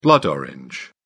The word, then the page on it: blood orange: British English